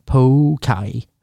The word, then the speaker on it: poke: Poke bowl. Men rätt säger man pokai. Tydligen då.